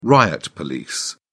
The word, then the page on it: riot police: British English